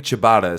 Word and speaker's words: ciabatta: But yes, the steak sandwich, is it ciabatta? I think ciabatta is how you say it.